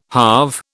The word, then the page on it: halve: British English